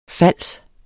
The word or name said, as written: fals